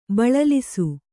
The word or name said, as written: ಬಳಲಿಸು